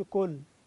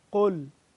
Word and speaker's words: قل: انتبه للقاف تخالف نطق الكاف، ما اقولش كل، كل دي كاف، قل من اقصى الحلق، قل اسمع، قل مش كل،